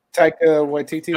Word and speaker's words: Taika Waititi: Taika Waititi? – Taika Waititi, That's got to be it.